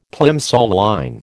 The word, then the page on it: Plimsoll line: American English